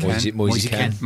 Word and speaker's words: Moise Kean: Yeah, get them in young, you know, which is, seems a little bit what we're doing with Mosey Kean, if that's how you say his name. – What is it? – Kean, Ken is Ken. – Mosey Ken.